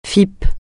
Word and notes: FIP: francais

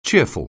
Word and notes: cheerful: English

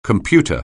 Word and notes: computer: anglais

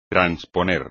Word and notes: transponer: espagnol

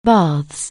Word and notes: baths: anglais

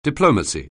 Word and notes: diplomacy: anglais

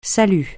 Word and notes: salut: francais

